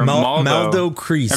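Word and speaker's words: Maldo Kreis: Valdo Crease? Is that how you say it? Or Maldo Crease. – I'm trying to look at the picture. That's an M.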